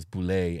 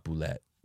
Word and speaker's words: bulette: It also clarified that, at least originally, the intended pronunciation was Boulet and not Boulette.